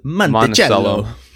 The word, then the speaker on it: Monticello: Monticello.